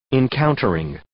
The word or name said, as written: encountering